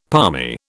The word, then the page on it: palmy: British English